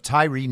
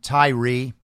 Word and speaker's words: Tyre: The headline Memphis official Investigators are looking into rumors of Tyree Nichols' relationship with former police officer's wife. And apparently, that's how you say his name, Tyree, not Tyre, as it looks. So, apologies for saying Tyre last week.